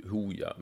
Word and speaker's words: Hooja: Hooja uttalas Hooja är en elektronisk musikgrupp från Gällivare bestående av Joakim Littner och Marcus Martin.